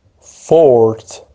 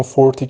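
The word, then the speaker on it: forte: فورتیفای فورتیفای فورتیفای فورت فورت فورت. این واژه تلفظ دیگه هم داره، اون فورتی که ای داره آخرش تلفظ دیگه هم داره. فورتی فورتی فورتی فورتی فورت جفتش هم تلفظ میشه.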